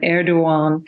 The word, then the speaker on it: Erdogan: but Erdogan.